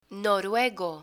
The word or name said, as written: noruego